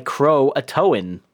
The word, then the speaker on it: Croatoan: That is spelled like Croatoan, like you're towing a truck, I suppose.